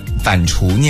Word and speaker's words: 反刍: Giant 一直在给我们说读音的问题啊，就比如说他说刚刚我把反邹念反刍念成了反邹，然后现在呢他又说我们把勤快念成了勤贵，是吗？